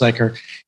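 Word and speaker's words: Zuiker: So the series creator, Anthony Zeiker, Zeiker, I think it's pronounced Zeiker, he also went on to write two books about Giles.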